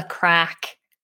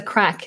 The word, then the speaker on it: craic: And crack isn't spelled as you would expect it, C-R-A-C-K. It's spelled C-R-A-I-C.